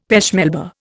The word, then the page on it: pêche Melba: Indian